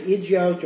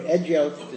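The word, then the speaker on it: Eduyot: So Maseches Igios, or Egios, it's pronounced in different ways. Egyot, yeah, like Ayin, Ayin Dalet Yud Vav Tav, is a very, very interesting and somewhat enigmatic tractate.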